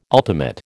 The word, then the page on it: ultimate: American English